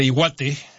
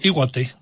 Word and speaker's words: Iwate: Este jueves, en las costas de Iwate, en el panorama internacional, en Japón, se registró, o Iwate, más bien sería la pronunciación, se registró un sismo magnitud 6.9, el cual no ameritó una alerta de tsunami.